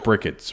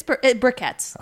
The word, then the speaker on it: briquettes: It looks like brickets, but... – Yeah, it's briquettes.